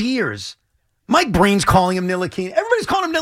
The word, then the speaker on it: Ntilikina: When he got drafted, I heard him pronounce his name. – And what did he say? – He did not pronounce it that way. He said Ntilikina.